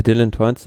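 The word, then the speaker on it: Dylan Teuns: Und wie spricht man ihn aus? Dylan Toins? – Ja, Dylan Toins,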